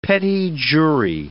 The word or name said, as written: petit jury